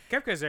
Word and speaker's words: Kefka: Kefka's how you pronounce the name, right? – Yeah, yeah.